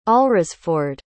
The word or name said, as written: alresford